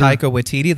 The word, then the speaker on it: Taika Waititi: Taika Waititi, the name that we have pronounced correctly many, many times.